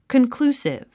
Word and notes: conclusive: kənklúːsiv; カンクルースィブ

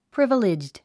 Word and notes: privileged: prívəlidʒd; プリバレェッジド

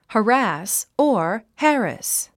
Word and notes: harass: hərǽs; ハラス

